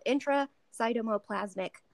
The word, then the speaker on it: intracytoplasmic: Embryo transfer, and this thing called intracytoplasmic sperm injection. Did I say that right? That's a big word. – Yeah.